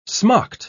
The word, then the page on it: smocked: British English